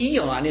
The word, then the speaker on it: aeon: It's pronounced eon in the English language.